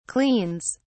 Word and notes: cleans: British English

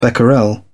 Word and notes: becquerel: British English

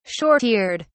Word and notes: short eared: British English

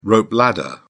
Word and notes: rope ladder: British English

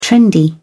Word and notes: trendy: British English

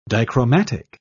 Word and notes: dichromatic: British English

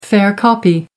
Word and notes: fair copy: American English

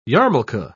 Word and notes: yarmulka: British English

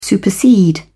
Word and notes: supersede: British English

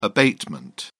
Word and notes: abatement: British English

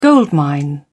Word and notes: goldmine: British English

